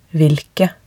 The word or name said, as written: hvilke